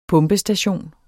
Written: pumpestation

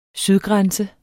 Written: sydgrænse

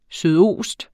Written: sydost

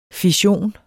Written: fission

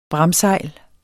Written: bramsejl

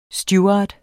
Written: steward

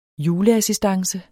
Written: juleassistance

